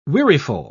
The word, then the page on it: weariful: British English